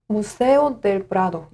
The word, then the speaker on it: Museo del Prado: 스페인어로는 뭐라고 해요? 무세오 델브라도 무세오 델브라도 Museo del Prado.